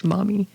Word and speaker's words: Maumee: Maumee, pronounced Maumee.